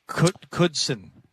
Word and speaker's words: Knudsen: Our engineer is Dennis Knudsen, but it's just pronounced Knudsen. The N is silent.